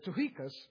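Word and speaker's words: Tychicus: When I send Artemas or Tuchicus, that's how you pronounce that. I know that's not how it looks, but it's Tuchicus, to you, do your best to come to me at Nicopolis, for I have decided to spend the winter there.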